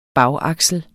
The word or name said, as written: bagaksel